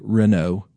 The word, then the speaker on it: Renault: Some people used to say Renault. Do you remember those when they were sold in the U.S.? It's actually Renault. Apparently, the French don't like to pronounce consonants at the end of words.